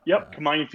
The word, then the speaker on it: Kamayan: Correct me on the pronunciation. Kamayan. – Yep. Kamayan feast.